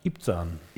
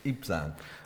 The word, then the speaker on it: Ibzan: What, how would you pronounce that? – Ibzan. – Ibzan.